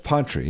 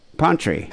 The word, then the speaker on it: Pontrey: How is it spelled? – P-O-N-T-R-E-Y. – Oh, yeah, Pontree, yeah. – Pantry.